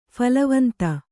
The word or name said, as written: ಫಲವಂತ